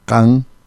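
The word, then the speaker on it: qan: Entonces, están los pronombres personales de ñoca, can, con Q, can, pai. Es más fácil. Pai con y griega. – Can, pero can. – Can, gutural can, también, sí.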